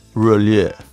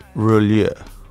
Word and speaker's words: Roulier: It's a French word and it's pronounced Roulier. Roulier.